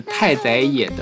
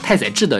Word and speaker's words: 太宰治: 嗯比如说这首歌是来自太宰野的太宰治的《女生图》，我一直把它读成太宰野太宰治的《女生图》。